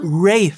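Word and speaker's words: Rhea: Weiß man, ob, ähm, ob äh, Ria spricht man den Vornamen aus, oder? – Ray, wie ich jetzt gelernt habe. Ich sag immer Ray. Ria, aber Ray.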